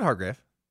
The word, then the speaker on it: Hargrave: You did the same thing I did on Friday where I just, for no reason, said Hargrove instead of Hargrave, and I just felt you. I felt for you for a moment. – Yeah, I said Hargrave.